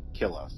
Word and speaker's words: Killa: They have a festival called, and I'm sure I'm going to mash up this pronunciation as well, Aya Marque Killa. Three words, A-Y-A, second word, M-A-R-C-A-Y, third word, K-I-L-L-A, which sounds like killer.